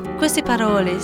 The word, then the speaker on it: Queste Parole: avec Queste Parole, c'est comme ça qu'on prononce? – Queste Parole, ces paroles, oui.